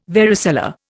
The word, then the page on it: varicella: Indian